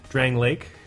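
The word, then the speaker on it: Drangleic: How do you pronounce the name of the place that it's set in? – Drang Lake. – Drang Lake. – Drang Lake. – See, I would have guessed Dranglaic.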